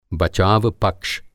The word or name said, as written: बचावपक्ष